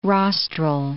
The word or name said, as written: rostral